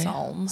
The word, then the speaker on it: Psalm: Do I pronounce the P? No, it's Psalm, right? – Psalm. – Psalm, like Kim Kardashian's son? – Yes.